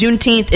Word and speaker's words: Juneteenth: But if you don't know what it is, Juneteenth is basically, it's pronounced Juneteenth because it's a combination of June 19th.